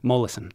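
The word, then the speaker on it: Molaison: Mollison.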